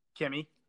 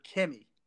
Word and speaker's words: Kimmy: We'll go with Cammy Granger. – Kimmy, not Cammy, but yeah. Sure. – I said Kimmy. – You said Cammy. – Like, I said Kimmy. I'm saying it right now, Kimmy.